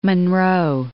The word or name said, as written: Munro